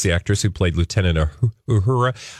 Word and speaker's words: Uhura: For some reason, I grew up always saying Uhuru. – It happens. – It clearly not. So every time I try to say it correctly, I always sound like I'm um, saying the wrong thing.